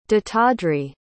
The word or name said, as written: dattadri